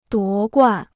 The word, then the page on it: 夺冠: duó guàn